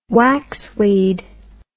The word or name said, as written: waxweed